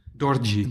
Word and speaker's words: Dorji: Uh, Dorry, zeg ik dat goed? Dorji. Dorji. Ja.